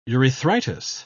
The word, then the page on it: urethritis: British English